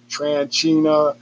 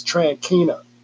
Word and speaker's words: Tranquina: And some folks, even though they see how it's spelled, they still call their last name Tranchina, Tranchino, it's Tranquina, all right?